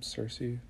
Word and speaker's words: Circe: Is that how you pronounce her name, Circe? Yes, sister Medea.